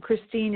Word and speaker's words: Christine: And Christine is spelled C-H-R-I-S-T-I-N-E. And Allison is A-L-L-Y, like yellow, S like Sam, O, N like Nancy.